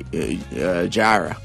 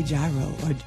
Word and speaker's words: gyro: All right, so how do you pronounce it? G-Y-R-O. – I say Jira. – I say Jira.